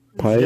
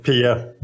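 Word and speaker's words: Piye: We'll finally meet, how do you pronounce that? P.A.? Pie? – Yeah, I just learned that I probably have pronounced it wrong in the YouTube show. It's Pia.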